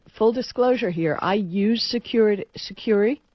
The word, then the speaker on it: Sucuri: You found an article about Sucuri, I guess it's pronounced. – Sucuri, yeah.